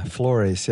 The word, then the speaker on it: Flores: Yeah, Flores, yeah. It's because it's pronounced with a Louisiana French accent, like it's Fleur.